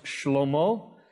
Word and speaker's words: Shlomo: Solomon's name in Hebrew is pronounced Shlomo, and it comes from the Hebrew word Shalom.